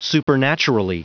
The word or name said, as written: supernaturally